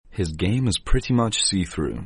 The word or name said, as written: see through